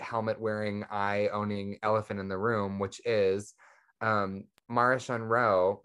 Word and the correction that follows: Marchion Ro: or how do you say his name? Marchion? – Marchion.